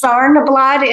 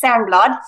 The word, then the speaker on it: Sarnblad: It's Sarnblad in English. – Okay, I totally did say that wrong then. Yeah, Sarnblad. Okay, and then what is that? – If you want to say it in Swedish, it's Sarnblad.